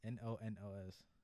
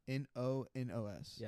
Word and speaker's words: Ñoños: It's N, but like with the squiggly, like the accent on it. – So okay. – Like, yeah, in Spanish. Anyway. – So N, like spell it. – Ñoños.